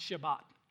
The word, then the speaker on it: Shabbat: What we translate as Sabbath comes from a Hebrew word, means it actually is pronounced Shabbat, okay?